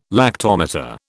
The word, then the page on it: lactometer: British English